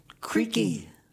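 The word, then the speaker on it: Creeque: And you looked up that it was actually pronounced Creaky.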